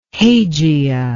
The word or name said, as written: hagia